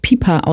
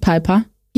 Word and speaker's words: Pieper: Der wird auch Pieper ausgesprochen, ne? Nicht Piper. – Pieper, ja.